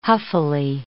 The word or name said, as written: huffily